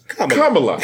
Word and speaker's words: Kamala: I know, but that's I think I saw online that they had it spelled like the pronunciation said like a comma. Like a comma in Kamala. A sentence. Kamala.